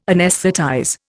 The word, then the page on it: anaesthetize: Indian